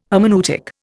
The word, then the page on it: hermeneutic: Indian